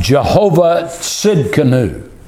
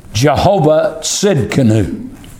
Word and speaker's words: Jehovah Tsidkenu: but it's pronounced Jehovah Tsidkenu. Jehovah Tsidkenu.